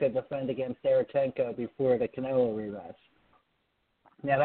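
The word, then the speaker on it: Derevyanchenko: The number two ranked fighter for the IBF is how do you pronounce his name? He's on the show. He trains with them. Derenchenko? – Yes, Sergey Derenchenko.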